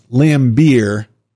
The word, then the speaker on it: Lambert: Not once has the Wall Street Journal, during its current expose, quote-unquote, dared to mention the vital information that Drexel Burnham Lambert, pronounced Lambeer,